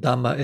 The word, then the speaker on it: dhamma: And dhamma is just the Pali pronunciation of the same word. Dharma is in Sanskrit.